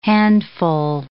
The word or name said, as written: handful